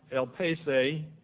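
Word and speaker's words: elpis: This word hope, spelled E-L-P-I-S, E-L-P-I-S, pronounced elpese, means expectation.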